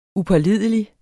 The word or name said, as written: upålidelig